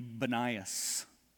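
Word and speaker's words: Banias: Today, in Arab tongue, it would be Benias. And that's because their P pronunciation is more like a B, because the Greeks just know it as Panias.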